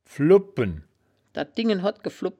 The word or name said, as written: flubpen